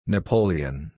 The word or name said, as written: Napoleon